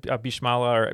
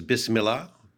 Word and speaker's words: Bismillah: Yes, we said Bismala or Basmala, I'm assuming, but that's what we locked in with. – I think the pronunciation is Bismillah, but no, given Bismillah.